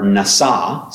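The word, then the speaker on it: nasa (נשא): The word for take is the word nasa, spelled like NASA, and it means to lift up.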